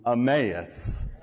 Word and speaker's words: Emmaus: The student was walking from Jerusalem to Emmaus. He had pronounced the A as a short A rather than a long A because he didn't understand how we as English speakers pronounce Emmaus.